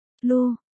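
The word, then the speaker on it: Lugh: Anh sắp, Lắp phát âm là Lu, là người anh hùng vùng Celtic hay còn gọi là thần mặt trời.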